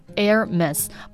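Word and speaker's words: Hermès: 所以说啊，其实它正确的发音应该是 Hermes，不要把那个 H 发出来了，不是 Hermes，不是这样的哦。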